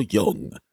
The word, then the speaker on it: Jung: Mr. Carl Jung. Is he white? – Yeah. – Is he? Because that's, how do you say, no, stop. – It's Jung. – Jung.